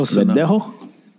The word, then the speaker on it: Verdejo: Felix Verdejo. – That was close. – So Verdejo. How you say it? – Wow. – Yeah, that's right. That's good.